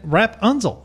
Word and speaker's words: Rapunzel: And then Rapunzel.